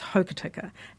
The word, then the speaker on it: Hokitika: I mean, over my lifetime, when I grew up, I told you I grew up in Hokitika, but when I grew up, it was Hokitika, and it still is that pronunciation for a lot of New Zealanders. But increasingly, it's becoming expected that we will give a Māori pronunciation to Māori place names.